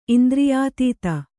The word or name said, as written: ಇಂದ್ರಿಯಾತೀತ